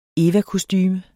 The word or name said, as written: evakostume